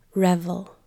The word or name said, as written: revel